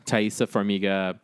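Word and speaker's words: Taissa Farmiga: Thaisa Farmiga, Thomas Middleditch, Alia Shawkat.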